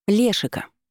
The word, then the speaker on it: Лешика: Лешика, с ударением на «е».